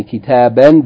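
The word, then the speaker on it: kitaba (كتابًا): Okay, and they said, note that a noun with tanween in the accusative case, yani two fatha, takes an aleph, which is not pronounced, yani if you keep reading, you don't pronounce it. But if, of course, if you stop, yani then you're going to pronounce it as I mentioned, yani kitaban becomes kitaba, qalaman becomes qalama.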